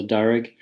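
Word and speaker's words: Darag: So Darig, second H pronounced like an I.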